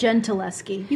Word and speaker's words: Gentileschi: Is it Gentileschi or Gentileschi? How do you pronounce that? – Gentileschi. You got it.